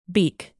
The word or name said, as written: beak